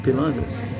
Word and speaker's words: Pilates: Pilates is the hardest, but yoga is the most. Is it pronounced Pilates? – Pilates.